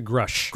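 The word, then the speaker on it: Grusch: How do you pronounce his name? – David Grush. – Grush. Sorry, Mr. Grush.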